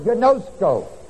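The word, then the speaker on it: gnosko (γινώσκω): It is not the Greek word for know, K-N-O-W, which is the word gnosko. Gnosko is spelled G-I-N-O-S-K-O.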